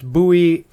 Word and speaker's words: Bowie: It is absolutely pronounced Bowie to rhyme with Louie.